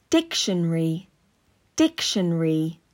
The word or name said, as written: Dictionary